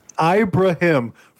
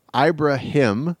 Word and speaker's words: Ibrahim: or Ibrahim, and ultimately he Ibrahim, for the love of God, we're gonna get you through these Gopher football.